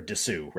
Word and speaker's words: Disu: Where it honestly, you're going to be like, well, Dylan Disu like played the best. And it's like, okay. Or Disu, right? That's how I'm supposed to pronounce it.